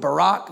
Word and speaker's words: barak: It's a Hebrew word, barak. You spell it B-A-R-A-K. Barak, it means to kneel or to bow down.